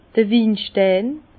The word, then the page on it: d'r Winstein: Bas-Rhin; Herrlisheim